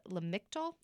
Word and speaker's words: Lamictal: Jim writes that they were once prescribed SSRIs and had negative side effects, but then was prescribed a drug called Lamictal, yeah.